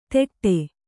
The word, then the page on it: ತೆಟ್ಟೆ: teṭṭe